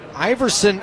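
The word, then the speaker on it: Aeverson: Iverson Arteaga, who, you know, it's pronounced like Iverson, like the last name of Allen Iverson, but it is spelled differently.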